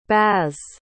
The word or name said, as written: baz